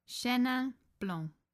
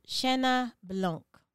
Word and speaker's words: Chenin Blanc: We'll do Cien Blancs. Is that the correct way to pronounce it? on YouTube. Liquor stores are still open, though. Isn't that great? That's one thing. That's it's like, is it essential to have the liquor stores open? Thanks. – Chenin Blanc. – Chenin Blanc. – Chenin Blanc. – Okay, so Chenin Blanc.